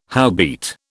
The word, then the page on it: howbeit: British English